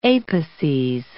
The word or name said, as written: apices